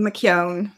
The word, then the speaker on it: McKeown: It's very hard to say right. It's Siobhan McKeown.